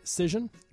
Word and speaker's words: Cision: We are back in here with Kevin Aykroyd, the CEO of, am I going to say this right, Cision? – Got it.